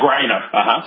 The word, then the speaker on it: Griner: He needs to call Putin, then get on an airplane, fly to Russia, and bring back Brittany, what is it, Griner? Is that how you pronounce her last name? – Yeah, Griner. – Griner.